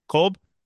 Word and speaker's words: Kolb: David Cobb or Kolb? Kolb? I've seen his name a million times and okay, great. Now I know.